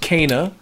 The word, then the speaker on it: Kena: I believe it's pronounced Kena.